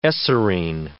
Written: eserine

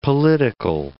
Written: political

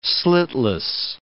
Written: slitless

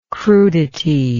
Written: crudity

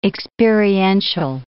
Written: experiential

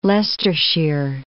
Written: Leicestershire

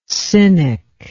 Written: cynic

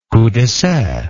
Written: coup d'essai

